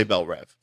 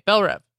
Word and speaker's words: Belle Reve: Dr. Light is in Belle Reve. How do you say that? – I would say Belle Reve. – Okay, Belle Reve.